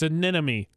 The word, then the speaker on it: anemone: It's anemone, like anonymity.